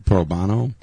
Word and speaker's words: pro bono: I think the actual thing is pro bono. It's pro bono. It is bono, not bono.